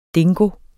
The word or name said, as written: DINGOER